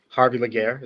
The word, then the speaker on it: Laguerre: So Harvey Laguerre is my name.